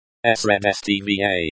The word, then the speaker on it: Средства: Средства. Colon, stress the first syllable. Средства.